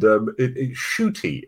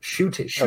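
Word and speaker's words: Ncuti: The mainstream audience did not know who they were going to get, and Shooty is how you pronounce the name. – Oh, is it Shooty okay.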